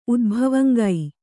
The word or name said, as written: ಉದ್ಭವಂಗೈ